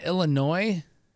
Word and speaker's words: Illinois: Say the state that is next to Indiana that starts I-L-L. How do you pronounce it? – Isn't that Illinois? – Oh, you didn't say an S at the end. So many people say Illinois.